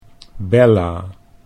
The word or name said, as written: Bella